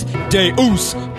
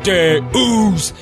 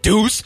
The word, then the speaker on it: Deus: The correct pronunciation is Deus, Deus. It's not deuce. A deuce is what you drop in the toilet.